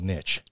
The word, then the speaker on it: niche: Just remember that it doesn't rhyme with bitch, which is the thing you want to call her when she corrects you about the word niche.